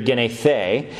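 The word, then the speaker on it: genethe (γεννηθῇ): And so this word is born, genethe. It's passive. That's how you pronounce it in Greek, genethe.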